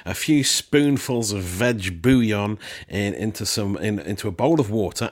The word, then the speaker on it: bouillon: It's just like, yeah, I suppose it's just water with bouillon. That's it. You're laughing. – Man, I'm so glad that everyone's loving bouillon.